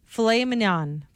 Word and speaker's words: filet mignon: The way that this is spelt is not how it is said, and it throws me off every time. Flamin' Mignon. Filet mignon.